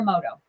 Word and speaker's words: Emoto: If I'm saying it right, Emoto, Dr. Emoto.